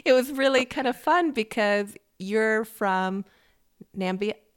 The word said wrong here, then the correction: Namibia: Pronounce it for me. Nambia? – Namibia. – Namibia. – Namibia, yeah.